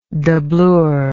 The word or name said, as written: doublure